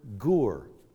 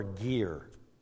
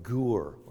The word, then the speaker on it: gur: It's the Hebrew word gur, or gir, pronounced both ways. The Hebrew word gur, or gir.